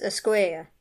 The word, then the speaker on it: Escuella: His last name is actually pronounced Escuella. Escuella.